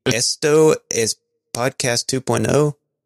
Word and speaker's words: Esto es podcast 2.0: Esto Es Podcast 2.0.